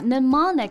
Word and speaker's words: mnemonic: Yeah, mnemonic devices can be a lifesaver when it comes to remember important facts or details.